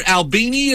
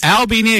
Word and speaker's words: Albini: Peter Albini is with us. Did I pronounce that right? – You did. Most people get it wrong. It is nice. Albini, a guy named Al wearing a funny hat.